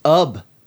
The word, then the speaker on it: Ub: Aaron Sullivan and Andrew Fific wrote in to inform us that the classic Disney animator's last name is pronounced Ub, like Chubb, as Andrew puts it.